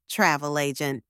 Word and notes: travel agent: anglais

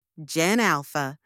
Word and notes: Gen Alpha: anglais

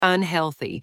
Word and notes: unhealthy: anglais

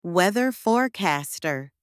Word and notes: weather forecaster: anglais

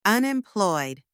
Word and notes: unemployed: anglais

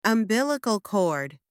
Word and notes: umbilical cord: anglais

